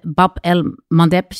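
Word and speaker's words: Bab el-Mandeb: En die aanvallen eh, eh, in die Rode Zee, die, die gebeuren, als ik het goed begrijp, in de Bab el Mandeb zeestraat. Zeg ik dat goed? – Ja, klopt. Ja.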